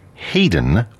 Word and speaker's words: Haydn: Before we hear music of Joseph Haydn, we have something by an English composer who, as his first name, it has the same last name as Haydn, although it's differently pronounced because it's English. Haydn Wood is his name.